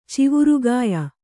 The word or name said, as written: ಚಿವರುಗಾಯ